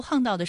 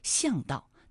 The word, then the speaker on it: 巷道: 那其实在读夯道的时候，可能有些朋友就会误读为巷道，那其实是不对的啊。我们要记住它还有另外一个读音呢，就是夯，夯道。